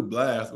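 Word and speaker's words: Blxst: Yeah, I never knew Blast was how you say his name. That's BLXTS.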